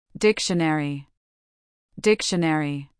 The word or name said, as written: dictionary